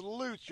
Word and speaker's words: Lutz: I know it's Lutz. I'm working on it. – It's Lutz. – It rhymes with boots.